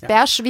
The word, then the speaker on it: Bärsch: Also das Bärsch wie das hessische Berg.